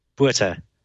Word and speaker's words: Botha: It's spelled B-O-T-H-A, but it sounds like? – Buta.